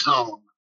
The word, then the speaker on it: Huang: Well, it's Huang. I mispronounced it.